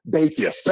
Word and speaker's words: Bekius: Bakius.